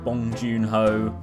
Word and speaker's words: Bong Joon-ho: Oh, in terms of new releases, the venerable Korean director Boon Jong-ho has got a new film out called Parasite. Sorry, I pronounced his name wrong. Bong Joon-ho.